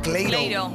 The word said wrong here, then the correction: Clairo: Cleiro. ¿Así se pronuncia? – Clairo. – Clairo, Clairo.